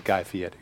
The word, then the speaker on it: Guy Fieri: He told me that's how he pronounces his last name. I know he was born Guy Fieri, but now he pronounces it Guy Fieri.